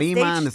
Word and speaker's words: tarima: They were getting fans' phones and then doing, like, either Instagram Live or taking selfies and how do you say that? – Tarima. – There you go. I was going to say harina. What the heck? – Harina, trae hambre, la beca.